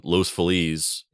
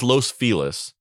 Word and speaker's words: Los Feliz: which is a restaurant in Los Feliz in Los Angeles. It's pronounced as Los Feliz. It should be pronounced as Los Feliz. That's how you say it in Spanish, but they call it Los Feliz.